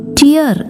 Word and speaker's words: tear: TEAR എന്ന വാക്ക് ടേർ എന്ന് വായിച്ചാൽ കീറുക എന്നാണ് അർത്ഥം. ഇതേ വാക്ക് തന്നെ TEAR ടിയർ എന്ന് വായിച്ചാൽ കണ്ണുനീർ എന്നാണ് അർത്ഥം.